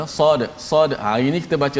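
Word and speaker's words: sad sad (صاد): baris dan sebagainya so nama sad tu apa ejaan sad macam mana eh, sad alif dal maka kita kena baca sad uh, ah, di hujung qalqalah tetap bunyi macam biasa walaupun rupa dal tak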